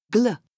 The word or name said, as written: gl